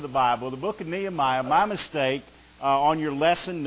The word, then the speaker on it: Nehemiah: Nehemiah.